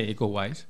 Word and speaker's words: Iko Uwais: No sé si lo he dicho bien. – Sí, Iko Uwais. – Iko Uwais,